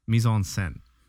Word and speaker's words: mise-en-scène: Mise-en-scène. You got to say it like a little Frenchy.